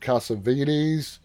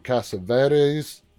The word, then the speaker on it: Cassavetes: Like I said, it stars Nicolas Cage, Sofia Boutella, Nick Cassavetes, or Cassavetes, whichever way you want to pronounce it.